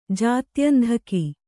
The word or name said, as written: ಜಾತ್ಯಂಧಕ